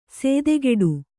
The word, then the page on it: ಸೇದೆಗೆಡು: sēdegeḍu